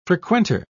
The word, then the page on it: frequenter: British English